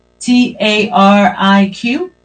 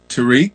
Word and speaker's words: Tariq: T A R I Q. – Tariq, yes.